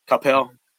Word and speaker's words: Coppell: And in the final in the white division, Coppell, is that right? How you say it? I always say it wrong. – Yeah. Coppell.